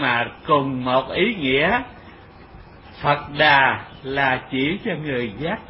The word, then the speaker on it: Phật Đà: Mà chữ Buddha thì trong chữ Hán dịch âm người Việt đọc là Phật Đà. Đó, là Phật Đà. Chữ Đa mình đọc là Đà, chữ Bút mình đọc là Phật.